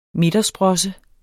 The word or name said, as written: midtersprosse